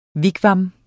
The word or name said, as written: WIGWAM